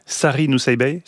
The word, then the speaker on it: Sari Nusseibeh: Sari Nusseibeh, c'est comme ça qu'on prononce. – Oui.